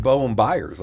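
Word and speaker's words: Bowen Byram: Because they don't know what's up with Bowen Byers. I finally said his name right.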